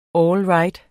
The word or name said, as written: all right